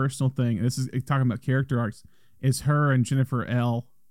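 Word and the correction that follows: Ehle: Is that how you pronounce her name? Look at that. Jennifer Illy.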